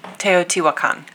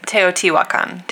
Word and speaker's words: Teotihuacan: I'm not going to be able to pronounce it correctly, but Teotihuacan? – Teotihuacan. – See, yeah, why can't I do it? Say it again. – Teotihuacan.